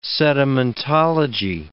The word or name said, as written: sedimentology